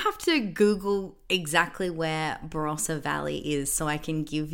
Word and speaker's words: Barossa: Is it Barossa Valley? – Barossa Valley.